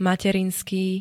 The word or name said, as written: materinský